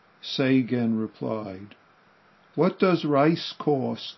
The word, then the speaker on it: Seigen: Seigen is the Japanese way of pronouncing the characters. It's in Chinese, I'll try to pronounce it, it's Qingyuan.